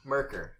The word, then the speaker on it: Myrkr: Y-S-A-L-A-M-I-R-I. – Okay, and what planet? – Can you pronounce that again? – Merkur. M-Y-R-K-R. – M-Y-R-K-R.